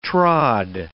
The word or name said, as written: trod